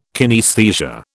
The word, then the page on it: kinaesthesia: British English